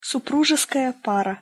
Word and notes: supruzeskaja para: Russian

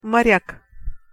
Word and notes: morjak: Russian

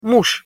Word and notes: muz: Russian

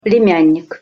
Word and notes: plemjannik: Russian

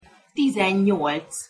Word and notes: tizennyolc: Hungarian

